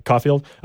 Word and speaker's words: Caulfield: I'm assuming it's pronounced the same Caulfield. – As Caulfield, but it's spelled differently.